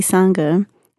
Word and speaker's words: Sangha: So when it comes to the work that you do at Nashville POC Sangha, am I saying that right? – Yeah.